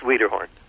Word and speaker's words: Wiederhorn: It's Wiederhorn.